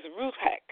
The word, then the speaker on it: ruach: The Hebrew word for breath and for spirit is ruach. Ruach. The word that's pronounced like you have to push it with your lip. Ruach. It's a whoosh.